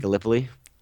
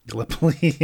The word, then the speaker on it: Gallipoli: Gallipoli? – Gallipoli, yeah.